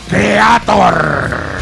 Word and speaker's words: Kreator: Mi mira que soy de pronunciar lo mejor posible los nombres en inglés, pero es de Creator, nada. Creator.